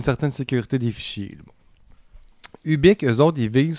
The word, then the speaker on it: Hubic: Et ça s'écrit H-U-B-I-C. – Oui, c'est ça. – Ubiq.